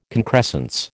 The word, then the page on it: concrescence: American English